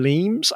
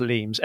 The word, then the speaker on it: Lemes: Leems. I think it's pronounced Leems, L-E-M-E-S. It may be Lemis, but we'll call him Leems.